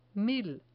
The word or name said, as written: mil